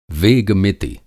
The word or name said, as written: वेग-मिति